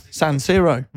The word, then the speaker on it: San Siro: How would you say it? – San Siro. – Right. Yeah. So you got that right.